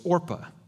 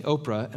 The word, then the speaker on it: Orpah: Uh, and people kept mispronouncing Orpah and saying Oprah,